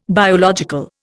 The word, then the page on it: biological: Indian